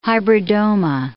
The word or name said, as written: hybridoma